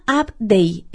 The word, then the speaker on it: UpDay: Se llama UpDay. En realidad es como... Se escribe UpDay, U-P-D-A-Y, el day como el día en inglés.